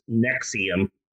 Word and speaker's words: NXIVM: which they pronounced Nexium, and Keith Rainier adopts the title of Vanguard.